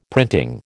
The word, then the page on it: printing: American English